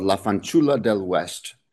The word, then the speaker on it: La Fanciulla del West: It's called La Fanciulla del West, the Golden Girl of the West, as it's commonly called in translation.